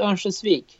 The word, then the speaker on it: Örnsköldsvik: So you grew up in, and I gotta probably get the pronunciation wrong, Örnsköldsvik? Is that close? – Yeah, it's close. It's in Sweden, we call it Örnsköldsvik.